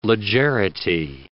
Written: legerity

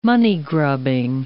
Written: moneygrubbing

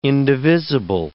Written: indivisible

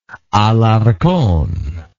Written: Alarcon

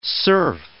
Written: serf

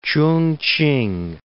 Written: Ch'ung-ch'ing

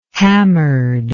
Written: hammered

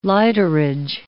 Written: lighterage

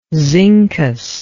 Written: zincous

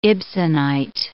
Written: Ibsenite

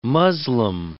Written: muslim

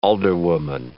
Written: alderwoman

